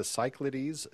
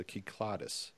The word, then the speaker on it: Cyclades: I always wanted to say the Cyclades because that's how I would pronounce it. But when I was over there a long time ago, it was pointed out it was the Kyklades. And that's the way I've been pronouncing it ever since.